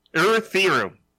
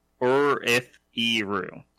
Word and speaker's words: Erythiru: Erythiru. I've been meaning to correct myself. – Excellent. Do we have anything for this? – I've been saying it wrong. – It's more like Asian. Erythiru.